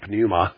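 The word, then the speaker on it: pneuma (πνεῦμα): The Greek word pneuma, our word pneumonia comes from it, or a pneumatic tire, an air-filled tire, we drop the P, we make it silent. In Greek, it's pronounced pneuma.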